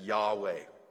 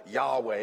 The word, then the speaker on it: Yahweh: resp- respond to or re- reply to as Yahweh.